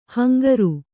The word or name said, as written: ಹಿಂಗಾರ